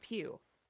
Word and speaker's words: Pugh: So I just say, you know, like the church Pugh, that's how you want to pronounce it.